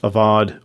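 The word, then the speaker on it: avad (עבד): And there's a Hebrew word, it's pronounced avad, which means to serve, and it can have that double meaning.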